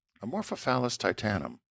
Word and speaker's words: Amorphophallus titanum: I think you pronounce this Amorphophallus titanum. Does that sound right? – Amorphophallus. I was. What did I say? Amorphophallus. – Yeah, Amorphophallus titanum. – Yeah. Amorphophallus titanum. Yeah, that's it.